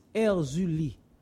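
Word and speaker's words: Erzulie: In French is Erzuli.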